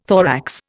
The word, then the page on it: thorax: Indian